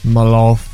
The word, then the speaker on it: Mehlhaff: All right, I got a letter here from Paul Melhaff. I think that's how you say that. M-E-H-L-H-A-F-F. How would you pronounce that? – Melhaff.